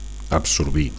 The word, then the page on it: absorbir: Catalan